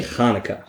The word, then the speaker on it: Hanukkah: Quick interjection though, is it Hanukkah or Hanukkah? – It's actually Hanukkah. – Hanukkah. – It's, Americans say Hanukkah because they can say ch.